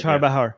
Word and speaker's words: Chabahar: Chabahar.